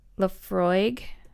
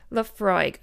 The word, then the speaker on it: Laphroaig: I had to Google how to pronounce it because, again, I'm not an expert. Laphroaig. Let me see. Laphroaig. Okay. L A P H R O A I G. Laphroaig.